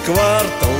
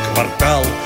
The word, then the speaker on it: квартал: Почти что целый квартал, а правильно квартал.